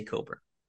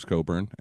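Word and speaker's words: Cockburn: Who's gonna read that and say, oh, it's clearly Coburn? – Exactly.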